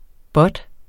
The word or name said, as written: bot